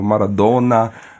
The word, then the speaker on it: Maradona: Diego Armando Maradona, wie er richtig ausgesprochen wird. Hier wird es ja gerne mal ein bisschen wie Doppel-N ausgesprochen, aber Maradona, auf jeden Fall ein Held, gottgleich verehrt.